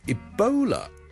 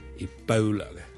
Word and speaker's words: Ebola: 最近咧，如果大家有睇国际新闻咧，都应该会留意到 Ebola 呢个伊波拉病毒咧，喺某啲地方肆虐噶。 Ebola 伊波拉病毒咧，我哋虽然广东话我哋译做伊。波拉波啦，我哋系 o 呢个发音。但如果系英文咧，我哋通常咧系读 ebola 嘅 ebola bow bow，好似弓箭嘅弓嗰个 bow。